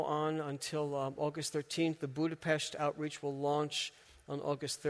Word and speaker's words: Budapest: Berlin, Budapest, it's spelled Budapest, but it's pronounced Budapest, and Moscow.